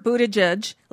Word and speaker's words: Buttigieg: Buttigieg for... – Buttigieg, like boot, yeah. – edge. – Edge.